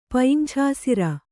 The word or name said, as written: ಪಯಿಂಛಾಸಿರ